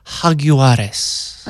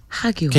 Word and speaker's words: Jaguares: Or as our sports people say, the Jaguares. It's a soft J, silent J. Jaguares. – Like hug. Jaguares.